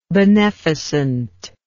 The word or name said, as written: beneficently